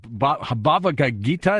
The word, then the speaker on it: Bhagavad Gita: Bhagavad Gita, is that how you pronounce it? – You did it very, very well.